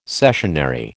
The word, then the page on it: cessionary: American English